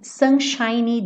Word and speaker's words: sunshiny: Não esquece desse Y, tá? Sunshiny. Porque ele tá fazendo o adjetivo, lembra que eu falei pra você? Sunshine, termina com E e o E é mudo, seria o brilho do sol, tá? E sunshiny tá fazendo o adjetivo. Então, esse Y tem que aparecer.